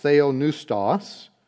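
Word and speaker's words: theonoustos (θεόπνευστος): It's pronounced theonoustos. It's a compound word. Theos, referring to God, and Nuao, to breathe or to blow.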